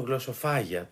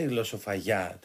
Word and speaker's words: γλωσσοφαγιά: Δεν έχετε ακούσει την έκφραση, Υπάρχει μεγάλη και συγκεκριμένα δεν ξέρω γιατί το τονίζουν γλωσσοφάγια, το λένε και λάθος και τονισμένο. Υπάρχει μεγάλη γλωσσοφαγιά τέλος πάντων μέσα στο σπίτι.